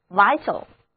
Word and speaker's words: vital: 这里的字母 I 要读作它的本音 I，而字母 A 在这里不发音， vital， vital。